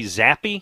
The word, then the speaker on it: Zappe: Malik Willis out of Liberty and Bailey Zappe, is that yep.